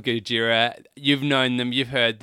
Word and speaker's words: Gojira: Normally, apparently it's called Gojira, but if you say it like it's like a Japanese action movie, it's like, oh, Gojira, Gojira. – I told you not to use it. – Oh, no, I think this is like a standard way of saying Gojira.